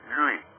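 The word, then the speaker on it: Llull: And then according to Raymond Lowe, actually the way that the Catalans pronounce that, since I knew somebody who's Catalan and told me this, the correct pronouncing of Lowe is Lui. It's L-L-U-L-L.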